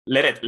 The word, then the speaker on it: Leret: es Leret, Leret, Es un apellido francés.